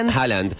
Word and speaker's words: Haaland: Bueno, el último hashtag tiene que ver con el Mundial Remo Vikingo con doblete de Haaland, ¿se dice así? – Haaland.